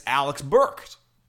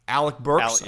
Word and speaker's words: Alec Burks: And it's Alex Burks. Alec Burks, I think is how you say his name.